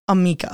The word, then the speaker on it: Amika: Amika.